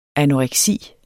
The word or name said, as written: anoreksi